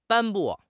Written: 颁布

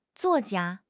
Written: zuo4 jia1

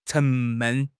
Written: 沉闷

